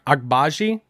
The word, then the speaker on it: Agbaji: Oh, Agbaji. Did I say that right for for Kansas? Is there a chance that he drops? – You said it right.